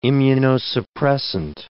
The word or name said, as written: immunosuppressant